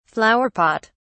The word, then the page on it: flower pot: British English